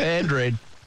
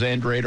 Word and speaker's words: Andrade: Is that how you say it? Andrade? – Andrade. – Like a G? – Yeah. – Or a J? – Yeah, well, yeah, she's, it's... – So not Andrade, but Andrade.